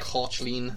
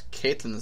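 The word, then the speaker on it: Caitlín: Cochleen. – Yeah. Well, that's the Irish pronunciation, so that would be acceptable. Anyways. – In fact, Caitlin is the wrong pronunciation, so get over yourself.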